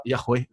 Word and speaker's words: Yahweh: Mais le plus probable, c'est que ça soit Yahweh, le nom de Dieu. C'est comme ça qu'il faut le prononcer.